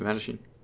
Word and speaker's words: Mattachine: Is that how you pronounce it, Mattachine? – Yeah.